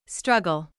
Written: struggle

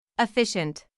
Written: efficient

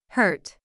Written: hurt

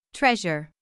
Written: treasure

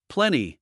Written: plenty

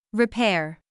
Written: repair

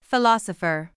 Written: philosopher

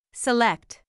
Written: select

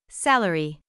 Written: salary

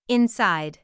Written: inside

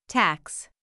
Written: tax

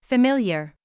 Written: familiar

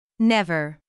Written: never

